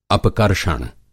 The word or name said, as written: अपकर्षण